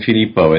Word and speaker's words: Filippo: And my name, Filippo, is spelled F like Frank, I, L like Larry, I, P like Paul again, and the letter O.